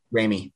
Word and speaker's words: Raimi: Please correct me if I'm saying that wrong. Raimi, thank you, who we're going to be so excited to talk about around the time of Multiverse of Madness, the sequel to Doctor Strange.